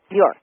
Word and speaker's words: Bjork: Tell us about Dr., and correct me if I'm pronouncing it wrong, Dr. Bjork. – Dr. Bjork. – Bjork. – B-J-O-R-K.